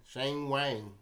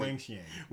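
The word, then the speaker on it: Wang Qiang: Simona Halep, Petra Kvitova, Kiki Bertens, Belinda Bencic, and Shang Wang. Is that how you say it? Uh, sure. No, that's not how you say it. How do you say Wang, it? Wang Xiang.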